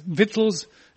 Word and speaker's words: victuals: it's pronounced vittles in England too.